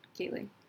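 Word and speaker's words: Kaling: Is it Kayling? – Kayling. – I think I'm saying it. It sounds weird. – No, you're saying it right.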